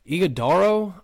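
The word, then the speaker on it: Ighodaro: Iguodaro, I think that's how you pronounce it. – That's how I pronounce it.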